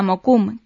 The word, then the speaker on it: Mokum: El disco se llama Mokum, que es como pronuncian los askenazíes la palabra hebrea makom, lugar en castellano.